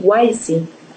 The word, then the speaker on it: Wisi: Se pronuncia así, Wisi. – Wisi. En inglés Wisi. Es Wisi porque viene de Wise and Easy.